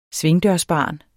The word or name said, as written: svingdørsbarn